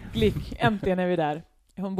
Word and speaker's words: Glück: Hon heter väl typ Glück? – Ja, det stavas Glück. Hon säger Glück.